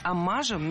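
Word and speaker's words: оммаж: Аммажи – это неверно. Слово «аммаж» имеет ударение на втором слоге, и при склонении ударение остается на том же месте.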